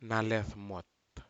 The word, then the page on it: nalefmut: Aneityum